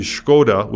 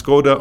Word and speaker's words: Škoda: So, yeah, do you pronounce Škoda or Škoda? I think the people in the know say Škoda.